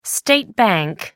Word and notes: state bank: American English

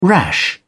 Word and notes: rash: British English

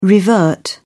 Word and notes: revert: British English

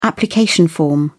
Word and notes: application form: British English

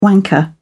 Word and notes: wanker: British English

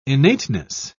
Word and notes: innateness: British English

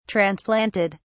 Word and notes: transplanted: British English

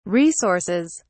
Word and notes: resources: British English

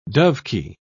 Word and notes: dovekie: British English

